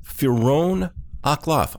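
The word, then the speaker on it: Pheeroan akLaff: My guest, if you're not familiar with him, Firon Akhlaf. I'm going to spell that P-H-E-E-R-O-A-N. Akhlaf. A-K capital L-A-F-F.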